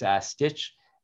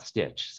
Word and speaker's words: Stich: If you misspell it like everyone does and put a second T in it, it will redirect. But it's Stich. I often say it's Stich, not Stitch.